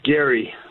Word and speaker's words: Gary: It's actually Gary. Hi, Dave. How are you? – Good, Jerry. Am I getting your name right? – No, it's Gary with a G.